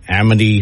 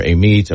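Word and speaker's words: Amite: And Amity for Amites.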